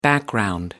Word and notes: background: American English

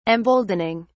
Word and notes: emboldening: British English

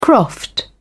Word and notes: croft: British English

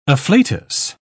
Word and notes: afflatus: British English

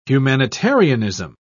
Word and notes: humanitarianism: British English

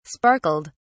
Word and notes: sparkled: British English

